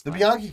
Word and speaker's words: Bianchi: Bianchi cover. Or Bianchi. Bianchi, that's how you say it.